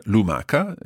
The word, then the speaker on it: lumaca: The singular, lumaca, is spelled L-U-M-A-C-A. And when that becomes the plural, lumache, we have to add an H in there in order to keep the hard sound of lumaca, lumache.